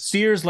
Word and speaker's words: Sears: Sears, like the department store? S-E-A-R-S? – Yes.